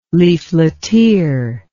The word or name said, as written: leafleteer